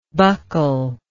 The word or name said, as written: buccally